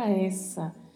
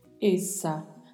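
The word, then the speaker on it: esa: Apesar de escrito com apenas uma letra S, o som permanece sendo de S. A gente está habituado no português a, tendo um S entre duas vogais, pronunciar com som de Z. – No espanhol, não. Então, apesar de ter apenas um S, vamos pronunciar com a mesma pronúncia que usamos no português. Essa. A diferença é que vamos fechar um pouco mais a vogal E.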